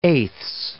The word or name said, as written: eighths